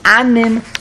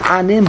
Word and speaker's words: Anim: The other says it's not anim, but it's en-am, en-aim.